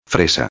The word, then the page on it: fresa: espanhol